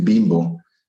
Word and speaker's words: Bimbo: By the way, the name Bimbo, which we pronounce it Bimbo, uh, is not a bad word.